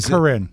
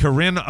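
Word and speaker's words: Carryn: One of the stories was about Whoopi slamming Karen Owens, the Navy SEAL widow, and is it pronounced Corinne or Corinne? It's C-A-R-R-Y-N. I don't want to mess it up. – I would think that'd be Corinne. It, Corinne Owens, the Navy SEAL widow President Trump honored during his recent speech to Congress.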